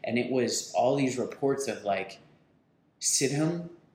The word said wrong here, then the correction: Stidham: I don't even know how to say his fucking name. – Stidham. – Okay. – Yes. Both Auburn QBs. – Okay. Stidham was their guy.